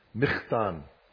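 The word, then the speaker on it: Michtam: We come to our second, and I'm going to try to pronounce it the way my wife taught me in Hebrew a little while ago, Michtan.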